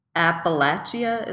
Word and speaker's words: Appalachia: I found that contradance has influence from English, Scottish, French dancing, and African influence from Appalachia.